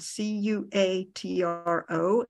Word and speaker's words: cuatro: Four is spelled C U A T R O, and it's cuatro, cuatro five, C I N C O, it's, it's spelled, and it said cinco, cinco.